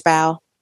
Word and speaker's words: bough: on the highest bough. – Yeah. – Not like bow and arrow. – No, absolutely not. – B-O-U-G-H.